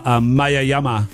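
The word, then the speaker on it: Maya Jama: Maya Yama. – Maya Yama. She's booked to be in season 2. – Yeah. – Joe Lycett, comedian. Ella's excited about Maya Yama.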